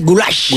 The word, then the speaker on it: goulash: El goulash es pronunciado así, goulash.